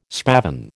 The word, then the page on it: spavin: American English